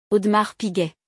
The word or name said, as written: Audemars Piguet